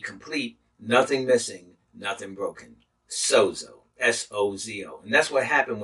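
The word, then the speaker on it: sozo (σώζω): This word saved comes from the Greek root word sozo, S-O-Z-O, and it's pronounced sozo.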